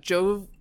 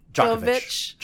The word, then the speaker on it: Djokovic: medical exemption well, the number one tennis men's tennis player in the world, Novak Djokovic. Djokovic. – Djokovic.